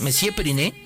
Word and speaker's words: Monsieur Periné: Sí, lo dije bien, Monsieur Periné.